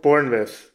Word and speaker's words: Bournemouth: Bournemouth. Sorry. It's spelled with the M-O-U-T-H, but, you know, those Brits.